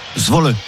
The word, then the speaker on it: Zwolle: Zwolle.